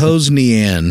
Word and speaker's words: Hosnian: Are we pronouncing that right? Since you listen to it, you know how things are pronounced. Yeah. Hosnian. – It's okay. Hosnian.